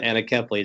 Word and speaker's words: anencephaly: It wasn't until, it wasn't until the death of my first child from a condition called anencephaly, or some pronounce it anencephaly.